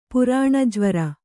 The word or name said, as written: ಪುರಾಣಜ್ವರ